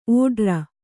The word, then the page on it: ಓಡ್ರ: ōḍra